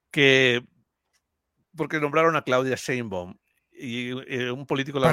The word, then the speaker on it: Sheinbaum: El mismo Mario Delgado no le sabe decir Sheinbaum. Lo de Sheinbaum, así.